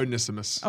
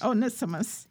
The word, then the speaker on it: Onesimus: Onesimus. – Onesimus.